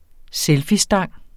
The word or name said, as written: selfiestang